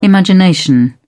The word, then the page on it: imagination: British English